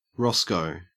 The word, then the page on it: roscoe: Australian English